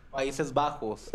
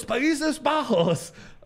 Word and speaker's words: Países Bajos: It was fun watching the game on Telemundo and then the, how do you say, Países Bajos in Spanish? – Países Bajos. – Yeah, yeah. Los Países Bajos.